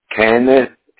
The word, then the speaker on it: Kenneth: Kenneth, K-E-N-N-E-T-H, Lynch, L-Y-N-C-H.